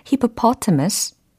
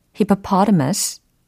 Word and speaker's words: hippopotamus: 두 가지 버전이 가능한데 hippopotamus, hippopotamus 이건데 이 발음 자체가 뭔가 힘이 굉장히 셀것 같은 그런 어감도 있어요.